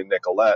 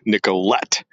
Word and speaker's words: Nicolet: It's a hard T, Nicolet.